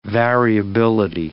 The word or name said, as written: variability